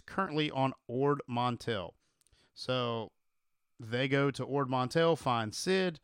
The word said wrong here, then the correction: Ord Mantell: It's Ord Mantell. – Ord Mantell.